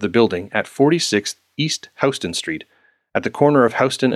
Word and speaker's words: Houston: I learned the hard way that while the street name is spelled the same way as Houston, as in the city of, if you don't want to look like some know-nothing tourist, it is in fact pronounced Houston Street.